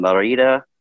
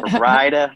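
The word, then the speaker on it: Mérida: They were down Merida. I'm trying to say it like the Mexicans do, but I'd say Merida when I first heard about it. Merida, Mexico.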